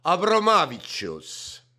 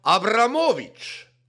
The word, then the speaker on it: Abromavičius: Majandusarengu ja kaubandusminister, ka nagu importkaup seal siis Aivaras, eesti keeles ei sobigi öelda, eesti keeles peaks olema siis Aivar, ja perekonna nimi on ka leedupärase hääldusega Abromavičius, mis võiks olla slaavipärasema hääldusega Abramovič, ikka Abramiga seotud tegelane nagu.